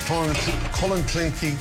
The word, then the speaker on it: Clonakilty: Irish tea bags or Clonakilty sausages from Cork. – Clonakilty. – Clonakilty.